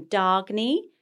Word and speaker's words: Dagny: It's pronounced in Scandinavian, Dagny. And it's spelled D-A-G-N-Y, Dagny, and it means new day started.